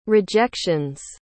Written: rejections

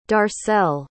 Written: darcelle

